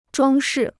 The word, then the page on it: 装饰: zhuāng shì